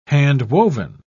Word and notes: handwoven: British English

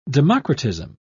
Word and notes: democratism: British English

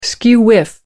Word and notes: skew-whiff: American English

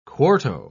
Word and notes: quarto: British English

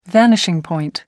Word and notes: vanishing point: American English

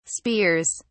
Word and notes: spears: British English

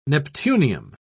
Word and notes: neptunium: British English

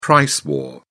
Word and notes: price war: British English

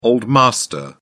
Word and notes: old master: British English

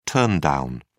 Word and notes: turndown: British English

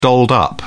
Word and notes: dolled up: British English

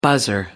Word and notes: buzzer: American English

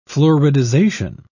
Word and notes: fluoridization: British English

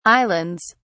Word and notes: islands: British English

